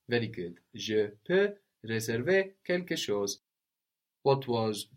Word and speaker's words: je peux: So that's where we get je peux from, P-E-U-X. And again, we have the silent X on the end, just like in je veux, I want.